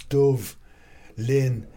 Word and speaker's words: Dubhlinn: but it's been anglicised because of all the English when English the English Empire came and started occupying Dublin, they couldn't pronounce Dove Lynn because BH it was originally called Dublin D U B H L I N N, and they couldn't they didn't know in Irish BH is V you would pronounce it like V so the English started saying Dublin, so that's how Dublin's come about.